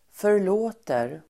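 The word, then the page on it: förlåter: för_l'å:ter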